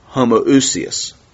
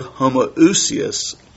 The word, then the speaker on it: homoousios: The word "substance" is the Greek word, pronounce it, Paul, homoousios. The Greek word homoousios literally means substance.